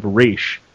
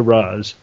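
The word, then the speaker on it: Ra's: First, yes, Michael, I grew up with the pronunciation from the Batman the Animated, se- animated Series of Raish as opposed to Raz, and I've often heard Denny O'Neil say it pronounced both ways in two different interviews, so that's even more confusing.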